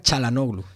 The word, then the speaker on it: Çalhanoğlu: Se pronuncia Çalhanoğlu, es que es difícil porque se escribe CA, pero se pronuncia Çalhanoğlu, todo el mundo lo pronuncia Çalhanoğlu.